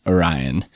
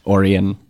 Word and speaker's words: Orion: Well, I would first like to stress that it is, in fact, Orion, like the constellation, like the Greek myth, not Orion, not Orion.